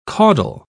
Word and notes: caudle: British English

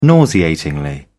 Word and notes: nauseatingly: British English

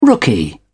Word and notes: rookie: British English

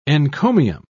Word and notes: encomium: British English

